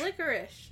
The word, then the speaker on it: licorice: Are you saying with a sh? Like sh? – Licorice. – Licorice? It doesn't have an SH. It's L-I-C-O-R-I-C-E. – I know, but that's how it's pronounced, is licorice.